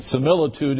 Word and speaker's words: similitude: I pronounced this similitude of God last week, and trying to justify the long I was not able to do so, and similitude is the right pronunciation for those of you who like to criticize.